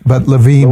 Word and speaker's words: Lewin: Yeah, people in English say Lewin, but Levine Lewin, would be the correct pronunciation.